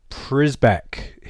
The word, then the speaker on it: Przybek: Debuts for Przybek, he has said, is the acceptable English pronunciation. Now, I think in Polish it's Szybek, something like that.